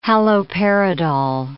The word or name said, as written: haloperidol